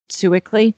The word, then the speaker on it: Sewickley: So if you would tell us how folks would find you and would find Sewickley, did I say it right? – Sewickley Hunt Club. Yeah.